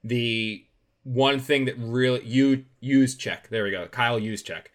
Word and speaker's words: Juszczyk: Like I had it set on how to pronounce his name. It's Juszczyk, the fullback number 44, the fullback for San Francisco.